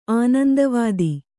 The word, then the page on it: ಆನಂದವಾದಿ: Kannada